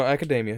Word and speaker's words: Academia: And they got My Little Witch Academia. Academy. I don't know. – No, Academia. You said that right.